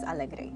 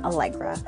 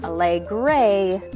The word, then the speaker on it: Alegre: The true pronunciation of my name is Alegre, which means happy in Spanish. However, since I live mostly in the English-speaking world and, let's face it, even my grandmother can't roll her R,